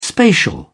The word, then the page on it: spatial: British English